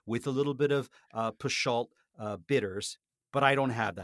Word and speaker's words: Peychaud: I assumed it was pronounced Peychaud. Show means hot. – Yeah, Peychaud. Yeah, that's the way you pronounce it.